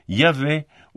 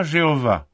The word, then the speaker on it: Yahvé: L'Encyclopédie juive déclare « La prononciation originale doit avoir été Yahvé ou Yahavé. » La prononciation de YHVH n'est pas Jéhovah. Elle n'est connue que depuis le XIVe siècle de notre ère.